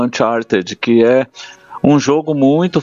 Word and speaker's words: Uncharted: O primeiro é Uncharted. Falei certo? – Falou. Fora do mapa.